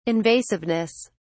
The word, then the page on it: invasiveness: British English